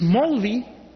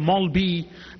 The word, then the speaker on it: Maulvi: Whether it's Bengali or Urdu, Punjabi, Gujarati or other languages, this Maulvi, the Mullah, the Maulvi, the Maulbi, now some people don't even pronounce Maulvi with a V, they call him Maulbi.